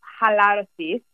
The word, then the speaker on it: Halarosis: Am I pronouncing that right? Hale so Rosas? – Yeah, it's Halarosis. So it's the Greek word for relaxation so originally, the company was started um, to bring non-toxic home fragrance um, to the market.